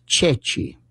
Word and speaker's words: ceci: One of them was that during the war, they would sometimes hold up a chickpea, and it's pronounced Ceci, C-E-C-I. But French could not pronounce that.